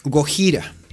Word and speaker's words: Gojira: Así que utilizaron, tal como se pronuncia en japonés, Gojira.